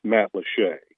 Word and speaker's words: Matlacha: And by the way, if you wanted to try to figure out where it's at, don't search like Matt Lachey with a Y. It's spelled Matt Latcha, M-A-T-L-A-C-H-A, but it's pronounced Matt Lachey.